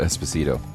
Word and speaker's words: Esposito: Giancarlo Esposito.